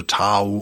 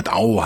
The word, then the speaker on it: Dao: Ich werde, äh, glaube ich, bei den westlichen Aussprachen bleiben, also Tao und Taoismus. Auch wenn es im Chinesischen eher Dao heißt, glaube ich. Ne? Also eher mit De vorne, weicher.